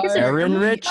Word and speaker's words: Ehrenreich: E H R E N R Ehrenreich. – I C H. – Ehrenreich.